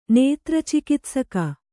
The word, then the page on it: ನೇತ್ರಚಿಕಿತ್ಸಕ: kannada